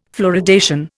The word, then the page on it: fluoridation: Indian